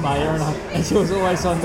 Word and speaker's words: Maya: So, we were pulled up for mispronouncing the Mayan people, or Maya. – In Mayans. – And we were calling Mayans. Mayans, apparently.